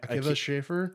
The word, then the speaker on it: Akiva Schaffer: Akiva Schaffer.